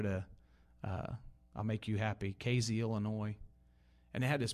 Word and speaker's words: Casey: But anyhow, one Sunday afternoon after church, we went over to that little town that none of y'all pronounce the right way. You know, Casey. People in Terre Haute put a Z in it.